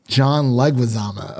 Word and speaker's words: John Leguizamo: John Leguizamo.